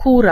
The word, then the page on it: kura: /ˈkura/